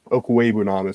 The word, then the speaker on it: Okwuegbunam: But between him, Fant, I mean, Albert O, I think, is the Okwuegbunam is how you say his last name.